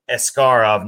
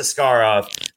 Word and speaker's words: Askarov: Yaroslav, well, okay, so I heard in the World Junior Championship, his last name is actually Askarov, not, so it's kind of like you can kind of pronounce it different ways, but I think it's Askarov.